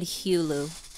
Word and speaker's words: Hulu: Yeah, it's also, it doesn't count DVRs and Hulu and all that stuff. – It doesn't count. – Which is insane, but I like the way you said Hulu.